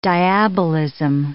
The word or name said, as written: diabolism